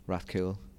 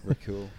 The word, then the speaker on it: Rathcoole: Rathcoole. – Or Rathcoole. – Rathcoole.